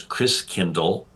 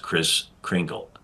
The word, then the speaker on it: Christkindl: And the German pronunciation of Christ child is Christkindl. And over the years, Christkindl got pronounced Chris Kringle. Right? So Chris Kringle, it's really Christkindl.